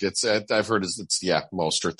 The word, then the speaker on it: Mostert: How do you say it? Mostert, right? With the fumble recovery. I think it was Mostert. – Yeah, correct. It's Mostert. That's right.